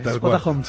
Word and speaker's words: Spotahome: El creador de Spota Home, ¿se dice así? – Sí, tal cual, Spota Home, sí.